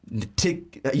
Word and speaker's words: Ntilikina: I'm going to fuck this up. Francis Ntilikina. You said it right.